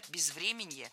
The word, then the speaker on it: безвременье: Но еще и инфраструктура, пострадавшая от бездеятельности или же там безвременье еще украинского прошлого. – Безвременье, неверно, правильно, безвременье. Ударение в этом слове падает на корень врем. Время, безвременье. Следующий пример попал в наш выпуск все из того же утреннего шоу.